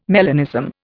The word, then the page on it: melanism: Indian